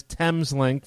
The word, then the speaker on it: Thameslink: There is a deep-level train system at St. Pancras called Thameslink, or actually the actual pronunciation is Thameslink for the River Thames.